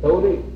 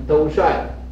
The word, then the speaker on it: 兜率: 呃，叫他于康熙壬子那那一年开法兜率，这个字是个本来是个帅字，但是应该画个圈，兜率天宫。一般人都是兜，呃，兜帅。呃，这个本来是，呃，要是按照这个什么，应该是兜率，啊，那么不过一般人都读成兜帅，啊，兜帅天宫，啊，那么并主卓溪，在卓溪那个庙上，他也做过方丈，啊，圣弘道法呀，那时候的，呃，这个佛法很盛行，啊，龙象，呃。